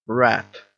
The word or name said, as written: rat